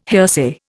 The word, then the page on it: hearsay: Indian